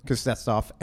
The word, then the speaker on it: Kuznetsov: Kuznetsov, and Ovechkin. – That's actually not a bad pronunciation of Kuznetsov.